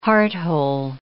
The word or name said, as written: heart-whole